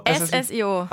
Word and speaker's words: SSIO: CEO, heißt das CEO? – SSIO. – Ach, den muss man so aussprechen, den muss man buchstabieren quasi.